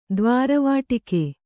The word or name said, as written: ದ್ವಾರವಾಟಿಕೆ